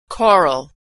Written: choral

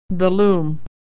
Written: loom

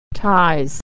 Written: ties

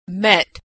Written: met

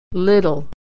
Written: little

